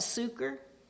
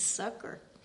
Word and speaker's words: succor: I think I looked that up and I have always pronounced that succor. I like it. I like the pronunciation that way, but it's not the correct pronunciation. It's succor.